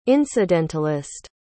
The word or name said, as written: incidentalist